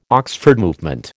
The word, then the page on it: Oxford Movement: American English